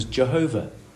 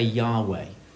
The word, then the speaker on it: Yahweh: The old guess was Jehovah. So you may have heard people talk about Jehovah, Jehovah's Witnesses. It's a guess of how to pronounce that four-letter word. Nowadays, it's guessed that it's a better pronunciation to say Yahweh. it's the same four-letter consonants, but it's pronounced in a more appropriate and realistic way.